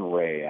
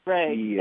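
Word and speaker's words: Rea: Tell me about Colin Rhea. And if I said the last name wrong, please correct me. But this is encouraging, right? He had sort of a debut to remember. – Yeah, it's Colin Ray, actually. But Ray, thank you.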